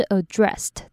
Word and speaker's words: addressed: 接下来是 addressed 这个字，最后的子音是无声子音，因为前面这个 ss 是无声的 s，所以这边的 ed 就要念成 t。